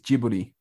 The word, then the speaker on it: Ghibli: And it's not pronounced Ghibli, it's Ghibli.